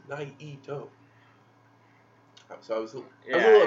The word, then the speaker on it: Naito: I was kind of mad because everyone was just doing two syllables, and... The Japanese people do three syllables with his Naito. – They go Naito.